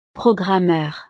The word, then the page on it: programmeur: frances